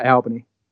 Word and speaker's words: Albany: Do you guys say Albany or Albany? Uh, Albany. Albany, yeah, right.